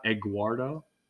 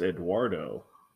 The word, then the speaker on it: Eduardo: Isn't there a G in there? Have I been saying it wrong my whole life? Is it Eduardo? It's not Eguardo? – No, it's Eduardo.